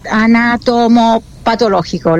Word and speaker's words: anatomopatológico: Luego, la cosa más importante que vamos a esperar es el examen, claro, anatomopatológico. ¿Lo dije bien? – Sí, impecable lo dijiste.